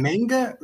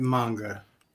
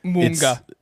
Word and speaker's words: manga: Is it manga or is it manga? – Just depends on how you want to pronounce it. – Yeah, that's really how it burns. As long as you're not pronouncing it like munga, it's anime books.